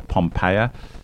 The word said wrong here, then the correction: Poppaea: sorry, Poppaea. I should Close pronounce enough. It correctly. Not Pompeia. Poppaea.